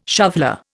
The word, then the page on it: shoveller: Indian